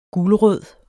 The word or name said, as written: gulrød